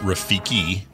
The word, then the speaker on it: Rafiki: What type of primate in The Lion King is Rafiti? Is he A, a baboon, B, a spider monkey, C, a vervet monkey, or D, a mandrill? – Um, well, one correction, it's pronounced Rafiki with a K.